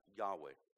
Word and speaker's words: Yahweh: And it's believed that the pronunciation was something like Yahweh.